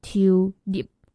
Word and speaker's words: tulip: 郁金香的台语一样是三个字，然后叫做 tulip 花，那个中间那个字啊，那个 lip 它是一个你嘴巴要 p 就我们英文的 p 不是那个有学过自然发音吗？就 a r b b c k，然后 p 发那个 p 嘛，所以它是那个 lip，就是有个 p 在那边，所以是 tulip 灰，然后还有一个往后缩、往后闭唇的那种感觉。